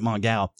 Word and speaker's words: Mongal: But Mongal, and yeah, that's how you pronounce it, Mongal.